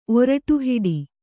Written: ಒರಟುಹಿಡಿ

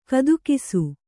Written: ಗುದಕಾಸ

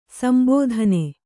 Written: ಸಂಬೋಧನೆ

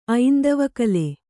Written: ಐಂದವಕಲೆ

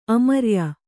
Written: ಆಮ್ರಾಯ

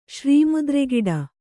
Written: ಶ್ರೀಮುದ್ರೆಗಿಡ

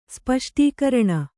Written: ಸ್ಪಷ್ಟೀಕರಣ

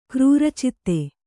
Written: ಕ್ರೂರಚಿತ್ತ

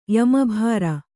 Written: ಯಮಭಾರ

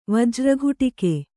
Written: ವಜ್ರಕೀಟಕ

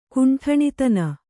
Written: ಕುಂಟಣಿತನ

